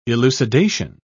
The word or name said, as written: elucidation